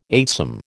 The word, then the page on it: eightsome: American English